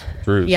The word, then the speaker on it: Druze: But so he was part of, I don't know how to pronounce this. It's D-R-U-Z-E. – I think Druze. Druze. – Yeah, the Druze.